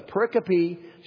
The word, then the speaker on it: pericope: We now read a story about the potential death of Moses' firstborn son, upon whose fate the focus of the pericope should naturally follow. Did you see how I pronounced that word correctly? Okay. Been working on it all week.